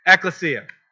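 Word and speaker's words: ecclesia (ἐκκλησία): And that is the word for, in Greek for church is ecclesia. One of those two. I put the wrong emphasis on the wrong syllable. Okay, ecclesia.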